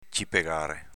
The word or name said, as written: cipegare